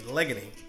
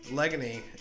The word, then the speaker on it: Leghowney: We stayed in Legony. Legony, you would spell it L-E-G-H-O-W-N-E-Y. Legony.